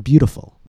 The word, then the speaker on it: beautiful: Now let's look at the word beautiful. First, let's find the main stress. Stress is on the first syllable, bu. The T comes after the vowel sound oo, and before the unstressed vowel, I. So again, we follow the rule and we pronounce it with the tap.